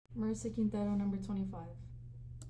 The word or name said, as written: Marissa Quintero